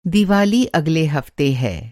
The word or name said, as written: हफ़्ता